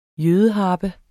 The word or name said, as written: jødeharpe